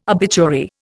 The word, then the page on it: obituary: Indian